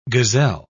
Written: gazelle

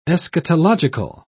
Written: eschatological

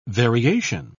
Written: variation

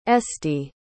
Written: esty

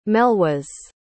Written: Melwas

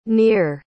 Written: neer

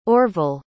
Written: orvel

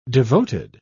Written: devoted

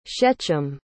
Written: shechem

